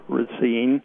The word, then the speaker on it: Racine: And put the emphasis on the second syllable, not like the new native of Racine who called a little bit ago. And if you say ra as in rah-rah Racine, or ah Racine, you won't get into any trouble.